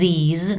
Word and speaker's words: these: Takže tito přede mnou je dlouhé i ve výslovnosti překouslí z, these, na konci je normální český z, these, these, these people, tito lidé, tyto knihy, these books, tyto problémy, these problems.